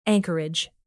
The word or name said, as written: anchorage